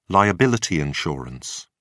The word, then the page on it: liability insurance: British English